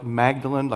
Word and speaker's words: Magdalen: It's spelled like Magdalene, like Mary Magdalene, but I'm told they pronounce it Magdalen.